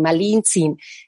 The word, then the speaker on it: Malintzin: Y como la R no se puede pronunciar, es Malina, Malinantzin, Malintzin.